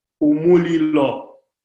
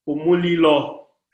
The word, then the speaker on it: Umulilo: The word for fire in Bemba is called Umulilo. – Umulilo? – Umulilo. – How do you spell that? – U-M-U-L-I-L-O. – U-M-U-L-I-L-O. – Yes. – Umulilo. – Umulilo, yes. – Umulilo. Umulilo is fire.